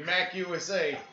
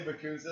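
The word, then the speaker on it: MACUSA: Yeah, we do magic our own way, Mac USA. – Oh man. – I think honestly, Makuza is how the British say it, and Mac USA is how we say it here in America.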